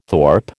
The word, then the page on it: thorp: American English